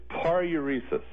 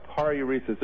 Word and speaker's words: paruresis: So, this palitosis... – Well, actually, it's paruresis. – Say what? – Yeah, paruresis. It rhymes with enuresis, if that's any help.